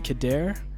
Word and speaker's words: Khadair: I never knew how to pronounce the Khadair. I always said Khadair. – You know what? That's a good question. – I've never met you before. So. – In total honesty, is Khadair really the right pronunciation? – Yeah, it's true.